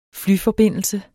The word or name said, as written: flyforbindelse